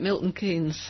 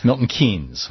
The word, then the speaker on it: Milton Keynes: Is it Milton Keynes or Milton Keynes? How do you say it? – Well, I say it Milton Keynes. – Milton Keynes.